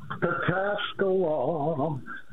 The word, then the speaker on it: Pataskala: Pataskala.